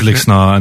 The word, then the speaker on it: Lixnaw: And it's Lixnaw, Fanoog. That's your two places. – Yeah, they're both tricky. We say Lixnaw, outsiders say Lixnaw. No, it is Lixnaw, and then people say we say Fanoog, but outsiders say Fanoog.